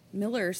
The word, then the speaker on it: Muller: Miller spelled Muller, interestingly. M-U-L-L-E-R, pronounced Miller.